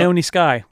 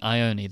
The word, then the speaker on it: Ione Skye: Basically, they're having a conversation, and Lloyd is talking about a girl that he likes, Diane, who's played by, help me, how do you say this first name? – Oh, yep. Ione Skye. – Ione, there you go.